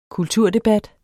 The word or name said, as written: kulturdebat